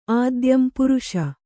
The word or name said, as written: ಆದ್ಯಂ ಪುರುಷ